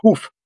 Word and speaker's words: Huff: Well, for English-speaking people, they pronounce it Huff. We came out of, we left Poland in 1846. – Oh. – My ancestors spoke German, so they... – Ah, right. – So in Europe, it's pronounced hoof. Like a horse's hoof. – As an H-O-O-F.